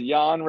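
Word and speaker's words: Jahn: It was there's a team called John Regensburg in Germany. – Yeah, there's just a guy's name. – I think it's pronounced Jan Regensburg, but yeah, that it's just a dude's name.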